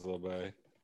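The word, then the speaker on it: Labbé: You told me I pronounced his name wrong. Or LeBay? – I think it's LeBay.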